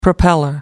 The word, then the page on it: propeller: American English